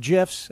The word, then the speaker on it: GIFs: GIFs, and it is a soft G, a cavalcade of them.